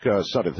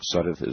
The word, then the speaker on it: Suddeth: Mark Suddeth. Is that the way your name is pronounced, Mark Suddeth? Is that correct? – That's right. Just like Skip Suddeth, the actor who used to be on Third Watch.